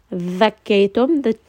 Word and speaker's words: ذَكَّيْتُمْ: Make sure that you do not pronounce this like a Z. The, not a Z. ذَكَّيْتُمْ. The teeth should be between the tongue here.